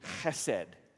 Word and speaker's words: chesed: The word in Hebrew is chesed. H-E-S-E-D. Chesed. That's how you say it. If spit doesn't come out of your mouth when you say it, you haven't said it right.